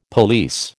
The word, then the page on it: police: American English